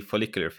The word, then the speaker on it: follicular: So that was the follicular phase.